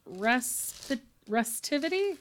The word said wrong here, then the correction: receptivity: Nope. – Recip- Receptivity. Receptivity. Receptivity. – Okay, there we go. Got that pronunciation.